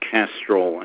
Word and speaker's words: Castroll: This is Dr. Robert Castrol, M.D. That's spelled C-A-S-T-R-O-L-L.